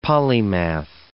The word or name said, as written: polymath